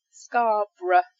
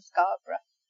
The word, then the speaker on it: Scarborough: So Scarborough, Loughborough. It's not borough, it's borough. So you don't pronounce it as two syllables, it's just one syllable here. So yeah, Loughborough and Scarborough.